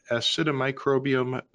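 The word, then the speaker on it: Acidimicrobium: I understand that Acidomicrobium bacterium A6 is the microbe that has been identified to degrade PFAS.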